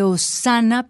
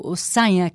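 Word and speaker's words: Osanha: Ay, pero es que se me ocurre una, se llama Canto de Osana, pero Osaña, que es una canción brasilera. Canto de Osaña, que se escribe O-S-A-N de niño H-A, Canto de Osana.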